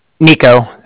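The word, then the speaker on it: Niko: Nico, so that's N-I-K-O, and okay. – The Bank of Cardiff is spelled C-A-R-D-I-F-F, as in Frank.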